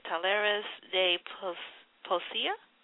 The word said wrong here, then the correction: Talleres de Poesía: Talleres de Poesía. – Ah, okay. Not too bad.